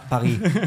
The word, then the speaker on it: Paris: París, la Cité de la Lumière, que pronunciará mejor. – Parfait. – Paris. – Très bien.